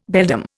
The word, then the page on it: beldam: Indian